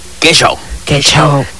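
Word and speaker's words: Que Xow: Ahí Que Show, por cierto, Que Show nos encuentras así como suena, Que, Q, U, E, y Show se escribe X, O, W. – Así. – Que Show.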